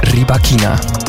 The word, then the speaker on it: Rybakina: De Kazachse, ik heb er een paar keer op moeten oefenen, maar het is dus Rybakina.